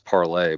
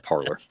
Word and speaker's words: Parler: Yeah, apparently it's supposed to be pronounced Parlay, but I mean a bunch of right wingers were on it, so of course it's just being called Parler.